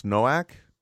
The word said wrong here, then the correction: Novak: Is that correct? – Sure. Novak. – Go. Novak. – Novak. – Okay, it's not a W, it's a double V in this case. – Well, there is it's no a W, but pronounced in Europe differently.